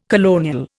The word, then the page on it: colonial: Indian